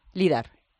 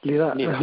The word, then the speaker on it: LIDAR: ¿LIDAR o LIDAR? ¿Cómo se dice? LIDAR. – LIDAR. – Bueno, pues LIDAR mejor.